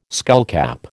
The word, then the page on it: skullcap: American English